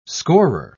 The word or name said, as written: scorer